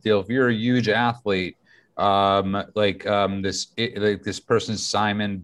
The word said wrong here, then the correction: Simone: Biles, is that how you pronounce her name? – Well, I thought this person was just Simone. Biles.